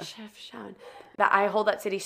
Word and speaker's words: Chefchaouen: Chefchaouen. – Yeah, oh my God, the Blue City. How did I forget that? – That city. I don't know if it's Chefchaouen.